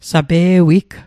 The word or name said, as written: sape'wig